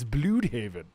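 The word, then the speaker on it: Blüdhaven: DC confirms that Bloodhaven has been pronounced wrong this whole time. It's Bloodhaven. – Well, that's what the umlaut means. It means bleh.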